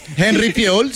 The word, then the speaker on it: Henry Fiol: Henry Fiol, ¿se dice así? – Henry Fiol, sí.